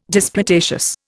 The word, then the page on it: disputatious: Indian